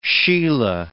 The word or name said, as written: sheila